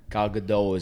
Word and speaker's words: Gal Gadot: Gal Gadot is how you say it.